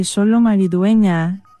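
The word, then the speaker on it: Xolo Maridueña: Solo Maridueña. – Exactamente, Solo Maridueña.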